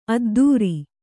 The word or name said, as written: ಅದ್ಧೂರಿ